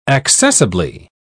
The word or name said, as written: accessibly